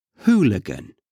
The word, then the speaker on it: hooligan: You know, who Again, that who sound, hooligan.